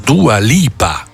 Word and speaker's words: Dua Lipa: Tant'è che io non sapevo che fosse questa Dua Lipa. Si dice così? – Sì, bravissimo. Si pronuncia così. Non ti puoi sbagliare.